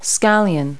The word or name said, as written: scallion